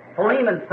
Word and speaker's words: Philemon: So, Philemon someone said back there, which I think is really the correct pronunciation of it.